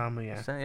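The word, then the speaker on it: Sentamu: no, this is Archbishop of York, John Centamu, am I pronouncing? – Centamu, yeah.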